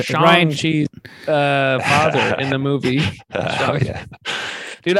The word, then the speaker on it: Shang-Chi: Sorry, it's pronounced Shang-Chi. – It's Shang-Chi. – It's actually Shang-Chi. – Is it Shang-Chi? Because they actually pronounce it in the movie. And it's Shang-Chi.